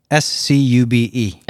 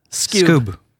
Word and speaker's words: Scube: S-C-U-B-E. – S-Cube. – Scoob. Scoob. Scoob. Think of this like just kind of like, you know, just a short name, Scoob.